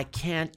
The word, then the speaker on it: can't: Lembre-se da pronúncia de can. Esse T não é pronunciado, é só a intenção. Então, você fala, I can't go. Você só dá a pausa. Você faz a boquinha do T ali, ó. Mas você não pronuncia o T.